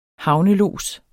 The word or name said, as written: havnelods